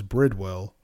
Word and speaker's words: Bridwell: Clifford's owner, Emily Elizabeth, is named after Birdwell's young daughter, who has the same name. Okay. Clifford almost had a different name. Oh, I was saying his name wrong. It's Bridwell. Was originally going to be named Clifford...